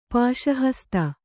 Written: ಪಾಶಹಸ್ತ

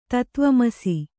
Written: ತತ್ವಮಸಿ

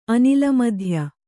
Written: ಅನಿಲಮಧ್ಯ

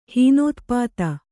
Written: ಹೀನೋತ್ಪಾತ

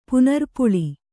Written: ಪುನರ್ಪುಳಿ

